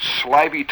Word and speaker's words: slithy: how to pronounce Slithy Toves. The I in Slithy is long, as in writhe, and Toves is pronounced so as to rhyme with groves.